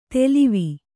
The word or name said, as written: ತೆಲಿವಿ